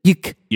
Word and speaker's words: Ryuk: They did it with Death Note, too, because Viz also did Death Note, where they pronounce that R, like, in Ryuk. – It's Ryuk. – But really, in Japanese, it's Yuk. – Yeah. – That's how they say it in Japan.